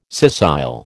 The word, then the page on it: scissile: American English